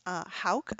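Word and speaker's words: Hauck: And his name is Dennis Hauk, I believe it's pronounced. It's H-A-U-C-K.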